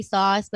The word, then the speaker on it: Sos: You can follow me at Shelby Sauce, but Sauce is spelled S-O-S because it's my last name.